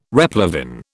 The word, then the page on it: replevin: British English